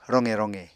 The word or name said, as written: roqe roqe